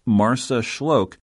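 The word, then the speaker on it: Marsaxlokk: The picture-perfect fishing port of Marsaxloc, pronounced Marsaxloc,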